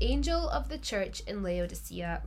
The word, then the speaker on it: Laodicea: Laodicea. – Oh, that's nice, isn't it? – Yeah. Laodicea.